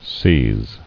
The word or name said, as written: Seizable